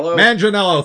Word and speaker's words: Manganiello: Manganiello, thank you.